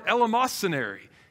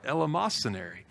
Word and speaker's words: eleemosynary: It's the word eleemosynary, E-L-E-E. I've got to look at it. I can't spell it myself. M-O-S-Y-N-A-R-Y, eleemosynary.